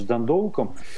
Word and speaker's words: Дандалк: Я на самом деле пока вот грешу на свою версию, и в этой связи будет очень интересен матч с Дандалком. – Дандалк. – Да, все-таки это более английское, да, такое ударение.